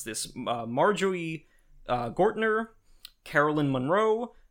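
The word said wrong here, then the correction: Marjoe Gortner: The first guy is Marjo Gortner.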